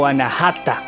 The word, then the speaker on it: Anahata: Luego tenemos al chakra denominado Anahata, o Anahata, como se pronunciaría en otros lugares, ¿no?